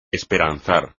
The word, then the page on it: esperanzar: espagnol